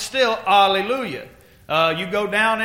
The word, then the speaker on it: alleluia: In America is where it started being pronounced with an H, and they said hallelujah.